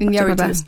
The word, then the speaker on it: Iñárritu: Iñárritu. Mm-hmm. That's probably a terrible pronunciation. – Iñárritu, I believe.